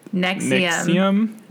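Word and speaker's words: NXIVM: I meant to look up how you pronounce Nexium.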